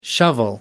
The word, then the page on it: shovel: American English